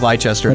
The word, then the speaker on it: Leicester: L-E-I. C-E-S-T-E-R. – Yeah. – It's like Leicester. – Leicester. It looks like Leicester. It's pronounced Leicester.